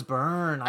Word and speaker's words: Byrne: Byrne? – Yes. – Ooh, Rose Byrne.